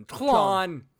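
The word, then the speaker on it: Tlön: T-L-O-N. Can't tell you how to pronounce it other than Tlon.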